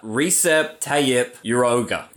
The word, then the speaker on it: Recep Tayyip Erdoğan: Recep Tayyip Erdoğan.